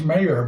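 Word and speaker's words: Mare: And the third one that I were watching is, it's pronounced Mayor, but it's M-A-R-A, Easttown, Mara Easttown.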